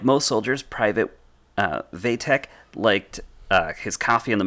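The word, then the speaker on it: Wojtek: So despite the fact that it's spelled W-O-J, that is pronounced Vatek, was an orphan in 1942 when he found himself in the care of Inka Bukiewicz, in a Polish refugee camp near Turan.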